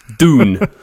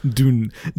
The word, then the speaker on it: Dune: D-U-N-E, the film. Dune. – Dune, I think it's pronounced. – Dune, that's the correct pronunciation, yeah.